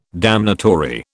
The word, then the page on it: damnatory: British English